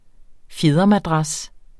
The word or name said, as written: fjedermadras